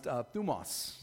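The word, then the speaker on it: thumos (θυμός): And the Greek word for wrath I pronounced as thymos. And a couple kids came up and they were like, why didn't you just say Thanos? You know, because they were big Avenger fans. But it's actually pronounced thumos. So I looked it up and it's called thumos.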